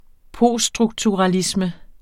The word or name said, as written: poststrukturalisme